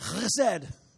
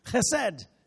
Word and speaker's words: chesed: And you have to say it like, you know how you have a cold and you have to clear your throat? You're like, chesed, chesed. My Hebrew rabbi friends tell me that. You have to say guttural, chesed.